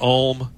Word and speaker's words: ULM: And that is so Northern Illinois, Louisiana Monroe, we pronounce that Ulm.